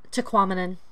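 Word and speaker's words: Tahquamenon: What's the Quetanamum Falls? I'm not saying it right. – Taquamenon. – Thank you, yeah.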